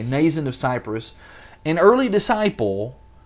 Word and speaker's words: Mnason: And some of the disciples from Caesarea went with us, bringing us to the house of Mnason, or I think probably just supposed to be pronounced with an N. Is that what you think? – Probably. – I think it's just Mnason of Cyprus.